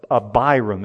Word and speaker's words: Abiram: It was at the cost of Abiram is how to pronounce that.